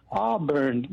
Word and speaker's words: Auburn: In Arbor, California, am I saying it right? – Auburn. – Yeah, oh, Auburn. Oh, okay.